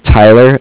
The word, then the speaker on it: Tyler: Yeah, my name is Tyler, T-Y-L-E-R, and I'm calling with Cardiff. It's spelled C-A-R-D-I-F-F, as in Frank Frank.